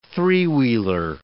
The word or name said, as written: three-wheeler